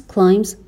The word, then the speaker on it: climb: Their confidence climbs faster than their competence. توی کلمه climb اون بی تلفظ نمیشه.